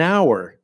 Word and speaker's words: hour: For example, when we have words that start with silent H, I'm going to stay for an hour. An hour. Hour begins with H, but if we say a hour, it loses that flow that it would have with an hour.